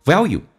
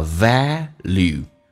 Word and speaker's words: value: value 美式发音，英式发音叫 value，美式发音这个 l 要提前发，叫 value，它这么发的。